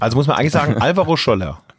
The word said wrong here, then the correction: Soler: ne? – Nee, Alvaro Soler.